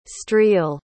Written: streel

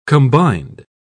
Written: combined

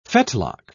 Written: fetlock

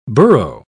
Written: borough

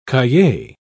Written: cahier

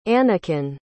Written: anakin

